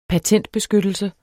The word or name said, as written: patentbeskyttelse